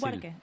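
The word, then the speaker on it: Buarque: Sí, y terminó con un temita de Chico Huarque. Nunca me sale bien decirlo. Chico Huarque, sí. – Lo dije bien, Huarque.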